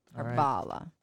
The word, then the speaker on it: Harvala: You emphasize the second syllable. Harvala.